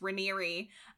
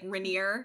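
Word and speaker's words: Raniere: The other stuff is that, A, I mispronounced the founder's name throughout the entire episode. It's Ranieri. I pronounced it like Rainier, because it's spelled like Raynor, like my Rainer.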